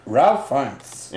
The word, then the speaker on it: Ralph Fiennes: Ralph Ralph Fiennes.